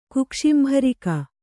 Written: ಕುಕ್ಷಿಂಭರಿಕ

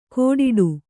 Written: ಕೋಡಿಡು

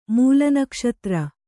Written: ಮೂಲನಕ್ಷತ್ರ

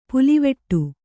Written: ಪುಲ್ಲಿವೆಟು

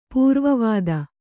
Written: ಪೂರ್ವವಾದ

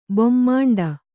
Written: ಬೊಮ್ಮಾಂಡ

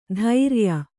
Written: ಧೈರ್ಯ

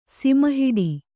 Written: ಸಿಮಹಿಡಿ

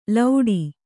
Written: ಲೌಡಿ

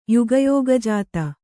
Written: ಯುಗಯೋಗಜಾತ

